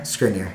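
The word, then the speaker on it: Škriniar: And then uh, Skrinner. Am I saying that right? Skriniar. Vic- Skriniar. – Yeah.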